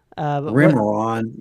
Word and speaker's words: Remeron: Can mirtazapine, Remerin, taken at night as sleep aid, elevate blood sugar levels in type 2 diabetics? I might have mispronounced half of those words. – Remeron. Yeah, we use a little bit of that.